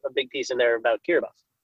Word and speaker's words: Kiribati: Kiribati is how that country's name is spelled. – Okay. – But Kiribati, Sex Lives of Cannibals.